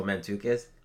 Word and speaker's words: Mantzoukas: Um, I don't know how to pronounce this guy's last name, but Jason Mantzoukas. – Oh, Mantzoukas.